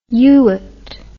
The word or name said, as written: ewart